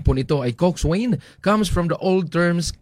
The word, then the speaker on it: coxswain: Ang pagbasa po ng coxswain, pero pag pronounce po nito ay coxswain.